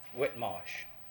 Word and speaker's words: Whitemarsh: It's spelled like White, W-H-I-T-E-M-A-R-S-H. – Mm. Would you pronounce Whitmarsh. It? Is it one word? – Whitmarsh.